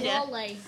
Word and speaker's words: Wale: So, no. Walde. Walde. – No, there's no D. Don't mock my pronunciation! – It's Wale. How do you not know how to say Wale?